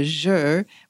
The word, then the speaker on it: Zhi: And the next one is the water one, and that's called the Zhi, which is spelled odd, Z-H-I.